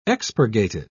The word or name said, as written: expurgated